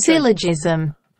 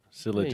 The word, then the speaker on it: syllogism: Syllogism. – Syllogism.